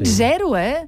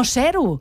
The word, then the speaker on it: zero: De zero, amb una S sonora. Ha dit zero, No zero.